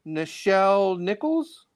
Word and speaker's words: Nichelle Nichols: But the reason why I was saying is because Nichelle Nichols, is that her name? Did I say it correctly? – Yeah.